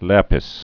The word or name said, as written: lapis